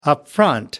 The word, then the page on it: upfront: American English